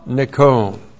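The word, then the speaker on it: nikon (νικῶν): Ha nikon. There's the definite article. And this is N-I-K long O-N. Ha nikon.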